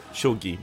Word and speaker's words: shogi: Ele é um jogador profissional de Chogi, não sei como pronuncia direito. Tanto que até o anime Shogi. – É meio... Shogi, muito obrigado.